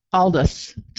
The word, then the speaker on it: Aldous: I want to say it's Aldous. – Aldous. Just Aldous. – Okay. Aldous Huxley. – A-L-D-U-S, even though it's not spelled that way. – Gotcha. – Yeah, Aldous.